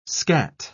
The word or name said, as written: scat